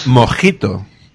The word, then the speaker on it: Mockito: Entonces ahí, por ejemplo, en el caso de Java utilizan uno que se, se escribe mojito, M-O-C-K-I-T-O, pero que realmente se llama mojito.